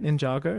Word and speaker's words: Ninjago: I mean, is that how you say it? Ninjago? – Yeah.